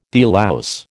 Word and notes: delouse: American English

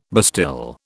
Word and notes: bastille: British English

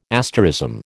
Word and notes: asterism: American English

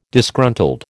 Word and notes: Disgruntled: American English

